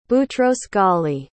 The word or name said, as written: boutros-ghali